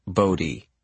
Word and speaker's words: Bodhi: "My friend Bodie is there," she said, pronouncing it Bodie. "He spells it B-O-D-H-I, and he's really nice.